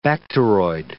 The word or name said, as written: bacteroid